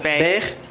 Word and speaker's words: Beghe: B-E-G-H-E. How do you pronounce that? – Beige.